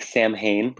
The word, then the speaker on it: Samhain: so it's spelled s-a-m-h-a-i-n looks like samhain but it's pronounced samhain